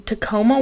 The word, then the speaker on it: Tacoma: They are a photographer from Tacoma, Washington. I think that is that how you say that? – That's right, yeah. – Did I say it right? Tacoma.